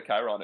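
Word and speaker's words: chyron: I mean, I just saw the Chiron at first.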